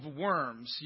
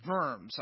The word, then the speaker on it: Worms: And that's how we pronounce it in English. Don't get confused. Some people think, wow, what were they making Luther eat? It's actually the German pronunciation is Diet of Worms.